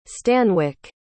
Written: stanwic

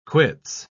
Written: quits

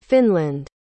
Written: Finland

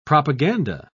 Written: propaganda

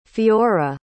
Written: Fiora

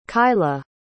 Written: kaila

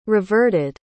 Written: reverted

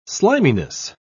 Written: sliminess

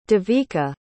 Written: devika

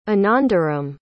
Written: anandaram

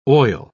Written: oil

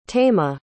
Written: taima

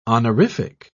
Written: honorific

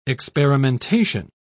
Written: experimentation

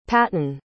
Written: paton

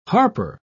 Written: harper

